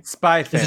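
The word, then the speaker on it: Spy x Family: In terms of like new stuff, I really like, I don't know how to say it correctly, but Spy Cross Family or Spy Family? – Spy Family. – Is it just called Spy Family? – I've been calling it Spy Family.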